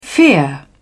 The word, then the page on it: fear: British English